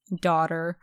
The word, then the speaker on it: daughter: Daughter. Enough. Like. – Wait, what's with daughter? What's not pronounced? – Well, the G, it's like, it's spelled doctor, basically.